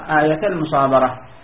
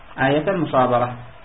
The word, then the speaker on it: آيتا المصابرة: أي آيتا، لكن لما التقى ساكنان، الألف في آيتا ساكنة، ولا اللام في المصابرة ساكنة، فأعتقد احذف اللين الذي قبلها، فمن حيث النطق تنطق آية المصابرة، آية المصابرة،